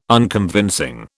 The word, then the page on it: Unconvincing: British English